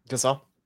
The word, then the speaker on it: Gasol: Gasol.